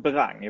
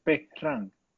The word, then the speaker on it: Behrang: Jag säger nog Berang i vanliga fall. – Nej, men det, så hade jag uttalat det på svenska. På persiska låter det så här. Behrang. Behrang. Behrang. Behrang. Inte Behrang, vad fan.